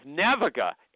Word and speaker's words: Naviga: She's the CEO of Naviga. NAVIGA. Did I pronounce it right or wrong? – Yeah, you did great.